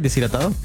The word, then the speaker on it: deshidratado: Y cuando estás deshidratado. Did I say it right? Deshidratado? – Deshidratado. – Yes.